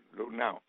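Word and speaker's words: Luhnow: Ustedes han visto que el ex gerente general de Houston, Lutnow, ¿se dice así? – Sí. – Lutnow.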